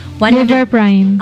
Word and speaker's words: Liver Prime: Amot, ugmao ba ni akong pag-pronounce, ani ha, pagbasa, Lipid Prime. – Liver Prime.